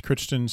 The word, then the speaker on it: Crichton: I believe his last name is pronounced Crichton, C-R-I-C-H-T-O-N.